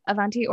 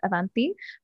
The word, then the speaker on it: Avanti: So my name is Avanti, or depending on the T, it's Avanti.